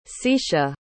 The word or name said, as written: secia